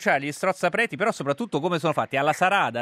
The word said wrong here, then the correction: saraca: ha detto? – Sì, la saraca.